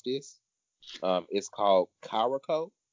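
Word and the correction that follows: Curaçao: Curacao. – Listen to our country asses. – We don't try to pronounce this. Curacao. – That's what it is. Curacao.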